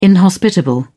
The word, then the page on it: inhospitable: British English